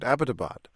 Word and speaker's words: Abbottabad: The military academy at Abbottabad, are we saying it correctly, by the way? Is it Abbottabad? – Yes. I think you are the one who's pronouncing it the best.